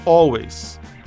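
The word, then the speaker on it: Alvvays: Antes, de Toronto, a gente escutou a banda Always. O nome é Always, mas se escreve que nem a palavra Always, mas usando dois Vs em vez do W.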